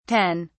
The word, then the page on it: 10: British English